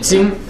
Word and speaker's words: Qing: Ça veut dire que, concrètement, l'Empire Qing, donc à l'époque, en Chine, c'est les Qing, ça s'écrit comme les Qing. Mais ça se lit Qing, Ça revient à dire que l'Empire Qing reconnaît, donc ils sont manchus, hein, les Qing, c'est pas les Han.